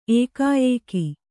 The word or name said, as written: ಏಕಾಯೇಕಿ